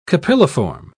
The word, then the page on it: capilliform: British English